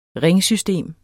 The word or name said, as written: ringsystem